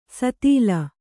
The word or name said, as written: ಸತೀಲ